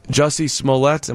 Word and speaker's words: Jussie Smollett: which is very interesting with the Jussie Smollett. Am I saying that correctly? – Yes, correct.